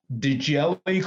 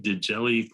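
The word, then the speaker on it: Djèli: Dujelle Clark. And his middle name is spelled D-J-E-L-I. – P. Dujelle Clark.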